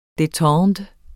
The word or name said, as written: detente